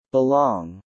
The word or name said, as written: belong